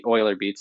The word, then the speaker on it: EulerBeats: So EulerBeats is really EulerBeats. That's the correct way to say it.